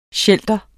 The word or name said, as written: shelter